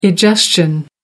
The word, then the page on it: egestion: American English